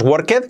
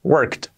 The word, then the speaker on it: worked: Cuidado, la pronunciación no es worked, como yo decía, cuando aprendí inglés es worked, con un sonido T al final, worked.